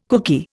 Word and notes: kooky: Indian

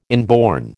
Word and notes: inborn: American English

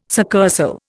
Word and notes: succursal: Indian

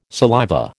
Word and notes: saliva: American English

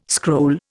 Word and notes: scroll: Indian

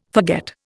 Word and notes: forget: Indian

